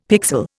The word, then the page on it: pixel: Indian